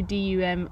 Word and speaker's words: Dumyat: Yeah, it's spelled really weirdly. It's literally Dumyat.